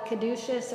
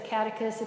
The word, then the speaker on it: caduceus: And there's a beautiful visualization called the caduceus or the catechus. It just depends on the pronunciation for whom you're hanging out with.